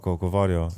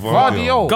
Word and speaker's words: Guardiola: Gavardio, yeah. I can't Governor. – Say his name. – Guardiola.